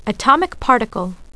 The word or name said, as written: atom